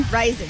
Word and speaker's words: Rison: 32 years ago in 1994, Lisa Lopes of the TLC set fire to the mansion of her then-boyfriend, Andre, how do you say it? – Rison? – Rison, yes.